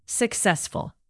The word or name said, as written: successful